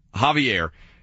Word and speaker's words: Xavier: She said that Health and Human Services, I think, Secretary, Xavier Becerra, or however you pronounce his name. – Javier.